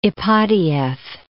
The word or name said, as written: Ipatieff